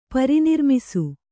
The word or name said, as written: ಪರಿನಿರ್ಮಿಸು